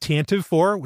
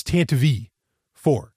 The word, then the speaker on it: Tantive IV: And by the way, it is, as far as I know, by all audiobooks that I've heard it mentioned in, it's not Tantive IV, which is what I thought it was always pronounced as. It's Tantive IV.